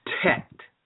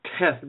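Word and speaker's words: tet (ט): It's tet. That's how it's pronounced. It's like teth, but I'm not pronouncing it exactly right. It has two different forms. And it's, as I mentioned, the ninth letter. Use the T as strong as you use the word T in tall.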